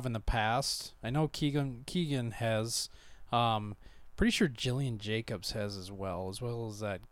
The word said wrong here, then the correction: Gillian: I think her name is actually pronounced Gillian. – Gillian Jacobs? – Yeah. Okay. I said Jillian Jacobs forever as well. Until I think somebody's like, it's pronounced Gillian.